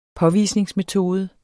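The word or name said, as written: påvisningsmetode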